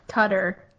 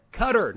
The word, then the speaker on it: Qatar: Um, it's actually pronounced Qatar. – Qatar. No, you're exactly right. – It is. Yeah, yeah. Yes, I learned that in college because I thought it was Qatar too until uh, I had an international class where we learned the correct pronunciation, and we also learned.